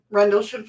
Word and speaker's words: Rendlesham: Missy Cat says she's also been to Rendlesham? – Rendlesham Forest.